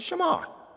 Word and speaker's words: Shema: It's the Shema. You do well because you're right. The Jews, they say the Shema. It's spelled S-H-E-M-A.